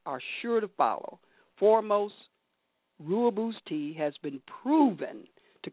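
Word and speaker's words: Rooibos: So we're talking about Ruaboos or Royaboos. I always say it wrong. My producer says it's Royaboos.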